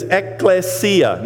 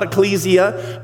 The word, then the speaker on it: ekklesia: The word, the original word phonetically is ekklesia, not ecclesia, but you can say that, it's okay. But ekklesia, and it means an assembly.